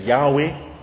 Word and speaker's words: Yahweh: The name is only written in letters, YHWH. We pronounce it Yahweh.